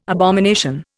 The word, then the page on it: abomination: Indian